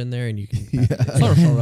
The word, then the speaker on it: Saoirse: I don't know how to pronounce it. Saurison? – Oh, Saoirse Ronan? – Saoirse. – Yeah, Saoirse Ronan.